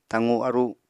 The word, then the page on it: taqu-aru: Apatani